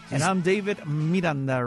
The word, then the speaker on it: Miranda: Miranda. Like the Miranda rights.